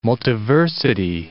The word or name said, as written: multiversity